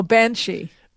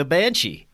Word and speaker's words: banshee: Now, one more thing I want to close out on, another entity that we have here in your talking points is the Bean Side. Is that, I pronounced that right? – Oh, Banshee. – Oh, Banshee. Wow. Yeah. All right. The Banshee. Yeah. – Sorry about that. Irish is Banshee, and I tend sometimes to forget and not write it in the English.